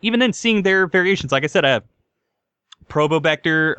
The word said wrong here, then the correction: Probotector: or I don't know how to pronounce it, Probector? – Probotector, I think it Probotector, is. Probotector, yeah.